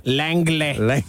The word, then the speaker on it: Langley: You gotta put that emphasis on the... It's a hard G. It's a G. – Langley.